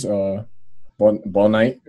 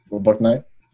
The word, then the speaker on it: Bouknight: Bouknight, that's how you say it. Bouknight.